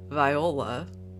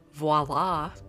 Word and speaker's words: voila: So I said Viola. I don't know why. Maybe because I was channeling She's the Man or something, I don't know. But anyway, it's actually properly pronounced voila.